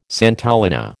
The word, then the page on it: santolina: American English